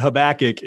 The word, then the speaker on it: Habakkuk: You know, one of the fun things we have at our organization is a long-running list of the mispronunciations of Habakkuk, like Chewbacca. Habakkuk. Yeah, Chewbacca, Hoobastank, Hoobacook. We get it all, but Habakkuk is the pronunciation that we utilize. Yes, sir.